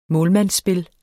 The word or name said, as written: målmandsspil